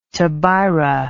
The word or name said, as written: tobira